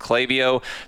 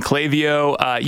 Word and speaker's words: Klaviyo: We've been saying Klaviyo. You can forgive anyone that pronounces it Klaviyo. The name of the company is inspired by clavija, the Spanish word for mountaineering pins, which support climbers as they ascend the mountain.